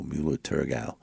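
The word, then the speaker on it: Müller-Thurgau: It's spelled Muller-Thurgau, Mulo Turgau.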